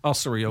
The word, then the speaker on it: Ossory: Ossory, okay.